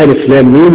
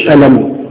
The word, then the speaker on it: الم: نون والقلم وما يسترون من ضمن الأحرف المفرقة اللي هي تأتي مفردة وإن أتت مجتمعة لا تنطق ككلمة ولكن تنطق أيضا إيه أحرف. يعني ألف لام ميم مفيش ألم لا ألف لام ميم كاف هاء عين صاد الكاف واحدة إلى آخره طبعا دي معروفة.